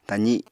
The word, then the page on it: tazi: apatani